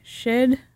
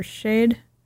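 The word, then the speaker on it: Sìth: The story that I have been mispronouncing with my shoddy American education as the Stolen Bairn and the Cid. It is actually pronounced the Stolen Bairn and the Shid or Shade.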